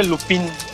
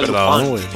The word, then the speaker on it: Lupin: L-U, se, escribe Lupin. – Ah. – Pero se pronuncia Lupin.